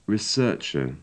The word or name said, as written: researcher